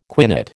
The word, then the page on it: quinate: American English